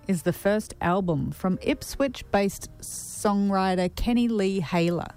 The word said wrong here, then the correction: Hellyer: Hellyer. I said that wrong.